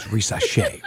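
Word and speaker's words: Risacher: As you said, Rosh Hashanah, however you say his name. Excuse me, I'm sorry. Rishashi. Zachary Rishashi. – It's Rishashi.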